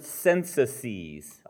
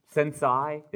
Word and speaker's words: censuses: How do you say the plural of census? Sensi? Is it a Latin word? Sensi, that sounds too much like sensei, doesn't it? Yes, sensei. Okay, Kayla's got it. Censuses.